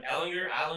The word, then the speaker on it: Ellinger: Ellinger. – It's a hard G.